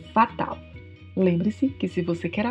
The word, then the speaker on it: fatal: E se você estiver horrível, péssimo, você pode dizer que está fatal, também com a pronúncia do L com a língua entre os dentes. Fatal.